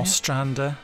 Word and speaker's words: Ostrander: Dead Man's Hand by John Ostrander. Is that how I'm pronouncing Ostrander. – It? – Ostrander. Yes, that was never...